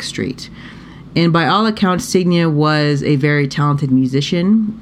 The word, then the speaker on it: Signe: But on August 11th, 1912, 31-year-old, and so her first name is a name that I was not familiar with how to pronounce, S-I-G-N-E, but I did look it up, and it's Signe.